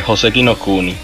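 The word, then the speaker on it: Joseki no Kuni (宝石の国): Otro anime que va para esta categoría es Land of the Lustrous, no sé cómo pronuncia esto. ¿Esto cómo se llama? – Eh, Joseki no Kuni.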